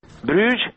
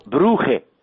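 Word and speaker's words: Brugge: Bruges sau Bruhe, cum sună pronunția venită pe gâtlești flamand, e o glorie trecută, dar ce glorie!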